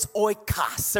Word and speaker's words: oikos: It's a Greek word, and I know some of you say oikos, okay? Rather, it's oikos or oikos. That's up to you how you want to pronounce it, but according to the Strong's Concordance Greek pronunciation uh, guide, it's oikos.